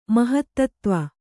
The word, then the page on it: ಮಹತ್ತತ್ತ್ವ: mahattattva